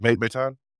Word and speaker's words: Maton: And this is Nick, saying his name correctly, Newton or Maton.